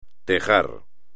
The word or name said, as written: tejar